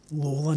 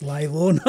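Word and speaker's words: Lalor: And that is how you pronounce the name, Lawler, not Laylaw, not like the suburb.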